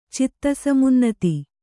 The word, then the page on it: ಚಿತ್ತಸಮುನ್ನತಿ: citta samunnati